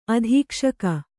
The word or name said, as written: ಅಧೀಕ್ಷಕ